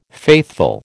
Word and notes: faithful: American English

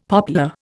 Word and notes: poplar: Indian

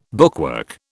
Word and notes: bookwork: British English

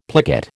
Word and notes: plicate: American English

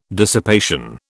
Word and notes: dissipation: British English

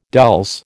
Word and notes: dulse: American English

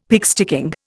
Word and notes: pigsticking: Indian

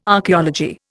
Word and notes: Archaeology: Indian

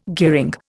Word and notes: gearing: Indian

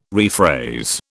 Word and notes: rephrase: British English

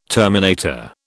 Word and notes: terminator: British English

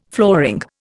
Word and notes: flooring: Indian

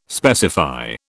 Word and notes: specify: British English

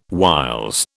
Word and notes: whiles: British English

